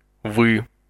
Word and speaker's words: Вы: Вы. Вы. – Phonetically speaking, this word would be pronounced like the two letters VY or V, but only slightly.